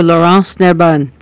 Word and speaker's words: Laurence Nerbonne: And is it Lawrence? How do you pronounce that name? – Oh, Laurence Nerbonne.